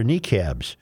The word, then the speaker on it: niqab: And some graphite drawings show the bodies of Muslim women wearing hijabs or niqabs, clothing that is usually meant to preserve modesty.